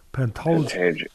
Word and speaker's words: Pantoja: Moreno coming off a loss to Pantanjo. Am I saying it right? He lost his title to Pantoja.